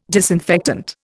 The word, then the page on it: disinfectant: Indian